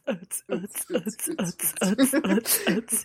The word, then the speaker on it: Utz: Is that how you pronounce it? – Yeah. It's Utz.